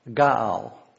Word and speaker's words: ga'al: Well, Redeemer here in this instance is the Hebrew word Gaal. G-A-A-L would be how to pronounce it.